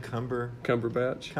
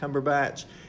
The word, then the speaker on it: Cumberbatch: Cumberbatch. – Cumberbatch.